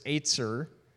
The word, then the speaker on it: ezer: Well, in Hebrew, the word is etzer. It's spelled ezer, but it's pronounced etzer.